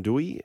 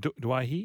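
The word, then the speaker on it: Doueihi: So the Lebanese brothers, if you like, Mitch Moses and Adam Dewey. – It's not Dwyer. – No, he was in the pronunciations. He wants to be Dewey.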